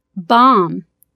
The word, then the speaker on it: bomb: Буква «Б», стоящая после буквы «М» в конце слова, не произносится. Послушайте примеры. – Jam. I am. Lamb. Limb. Dithram. – Bomb.